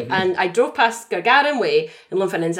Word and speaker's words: Gagarin Way: And I was going to suggest that we all club together and we buy a council house on Gargarin Way in Lumphinnons. – Pronounce it Gagarin Way. – Gagarin Way. Gagarin. Is it sorry? – Like sorry. – Yuri Gagarin.